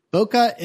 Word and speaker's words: bokeh: B-O-K-E-H is how it's spelled. Bokeh is how it's pronounced. We have evidence for it, at least, you know, the mid-1990s to early 2000s. And it's spelled, interestingly, because it ultimately comes from Japanese, bokeh.